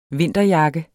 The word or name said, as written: vinterjakke